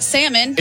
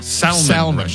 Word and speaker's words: Salman: It's definitely not salmon. – It's not salmon. – I thought Salman. Salman.